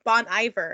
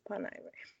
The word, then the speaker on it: Bon Iver: He's like Bon Iver. And I go, oh, well, no, it's pronounced Bon Iver.